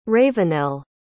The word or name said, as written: ravenell